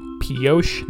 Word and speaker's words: Pioche: Pioche, Nevada, to be specific. That's how you say it.